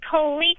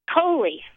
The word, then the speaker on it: Colie: Hi, this is Coley calling from Gunnison, Colorado. – What's your name, Coley? – Coley. – How do you spell that? – C-O-L-I-E.